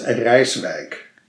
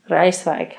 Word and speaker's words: Rijswijk: It is Rijswijk. – Rijswijk, Holland.